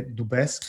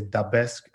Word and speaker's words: Dubesque: In Austria, people say Dubesque. So in the UK and in the US, people say Dubesque.